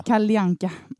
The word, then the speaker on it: Kalle Anka: ja, ja, då menar du Kalle Anka? Kalle Anka.